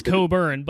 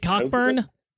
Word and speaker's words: Cockburn: Oh, I'm sure it says Coburn, but I'm also pretty sure that is a coordinated campaign to literally change how his last name is pronounced.